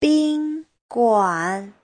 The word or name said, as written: bin1guan3